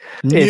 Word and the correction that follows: nuclear: Nucula. Nuclear Sorry. Weapons. – I did pronounce that incorrectly. – True. – Nuclear.